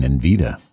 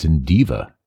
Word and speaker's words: Indiva: I might have been saying Invita, but it's Indiva.